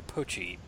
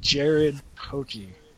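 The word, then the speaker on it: Poché: Okay, Jared Poch or Poche, P-O-C-H-E. – P-O-Z-H-E? – C-C. – Okay. C as a cat. I don't know.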